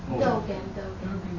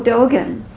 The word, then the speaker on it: Dogen: Sojun says Dogen. – Oh, Dogen.